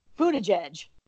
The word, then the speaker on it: Buttigieg: Because Pete Buttigieg is by far, I know I can't say his name, Buttigieg. – Well, he holds up this meme that says Buddha edge. Okay. – Yep. – So I try to say it fast.